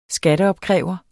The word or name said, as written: skatteopkræver